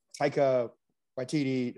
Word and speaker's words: Taika Waititi: But Rick, you brought up the new Taika Waititi. Did I say that right? – Correct.